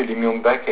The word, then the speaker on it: Lee Myung-bak: Bon, c'est comme ça que je sais que Lee Myung-bak a été élu président de la République. Hein. – Ah, mais tu prononces bien son nom.